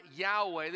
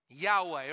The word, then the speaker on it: Yahweh: Most people pronounce that word Yahweh. They put an A and an E in it.